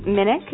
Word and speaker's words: Minich: It's Minich. – Thank you, Minich. – Minich, but I know. – It's Minich. – I need to just write a K in there so I say it right.